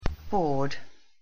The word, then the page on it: board: English